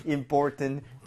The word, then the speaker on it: important: 자, 원래 제일 정직한 발음 그런 거는 그냥 important. 다 발음하는 거 important. 그게 제일 정직한 발음. 그리고 조금 뭐라 그럴까 부드럽게 간다면 important, tent 할 때 t가 나오는 부분을 flap을 내서 리을 비슷하게 important, important 이렇게도 하는데 어떤 사람들은 아예 그 t 사운드 자체를 안 한 사람들도.